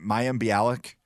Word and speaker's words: Mayim Bialik: Is it Mayim Bialik? – That's well done on the pronunciation.